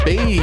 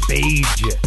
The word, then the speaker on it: beige: You said beige. – Did I? – Yes. – Beige. – What's up with the hard G? That's a weird pronunciation of the color beige.